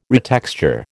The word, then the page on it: retexture: American English